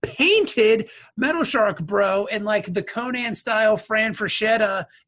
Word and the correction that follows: Frank Frazetta: Is that how you pronounce his name? – Frank Frazetta. – Frank Frazetta, like, in the Frank Frazetta-style, like, Conan painting.